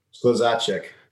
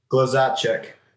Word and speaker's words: Skluzacek: Skluzacek. – Say it again. – Skluzacek. – That's not it. It's Skluzacek.